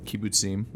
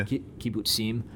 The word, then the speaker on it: Kibbutzim: and they put together, I'm going to horribly mispronounce it, but Kibbutzim? – Kibbutzim, yeah. – Kibbutzim, which were communitarian agricultural organizations where also the individuals in the kibbutz didn't own the property themselves.